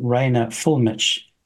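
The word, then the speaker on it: Rainer Fuellmich: I watched a Z Media video on Rainer Fulmich. Apologies for the pronunciation. – No, that's about right. You got that.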